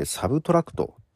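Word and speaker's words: Subtract: マイナスではなかったですね。エド・シーラン、これマイナスと書いてサブトラクトという風に読みます。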